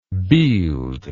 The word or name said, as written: bield